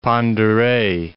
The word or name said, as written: Pend Oreille